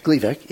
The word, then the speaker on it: Gleevec: Gleevec? – Gleevec, yeah.